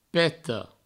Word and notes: peta: Provençal; peto

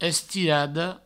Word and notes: estirada: Provençal; estirado

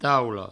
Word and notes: taula: provençal; taulo

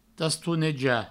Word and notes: tastonejar: provençal; tastouneja

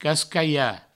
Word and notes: cascalhar: provençal; cascaia

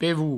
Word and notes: pevol: Provençal; pevou

